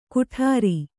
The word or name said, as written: ಕುಠಾರಿ